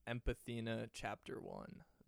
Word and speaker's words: Empathinoct1: So it's actually, the way I intended it was Empathina chapter one.